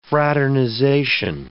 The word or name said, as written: fraternization